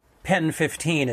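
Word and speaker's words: PEN15: Pen 15 is sort of the proper way to pronounce that title.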